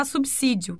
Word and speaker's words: subsídio: Com certeza, ele não responderá subsídio, que é a forma correta.